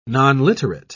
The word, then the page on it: nonliterate: British English